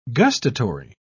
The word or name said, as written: gustatory